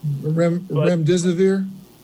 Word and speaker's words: remdesivir: Remdesivir? – That's it.